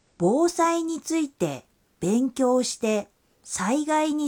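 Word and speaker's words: বোসাই (防災): এই কাঞ্জির উচ্চারণ হল বোসাই, বোসাই।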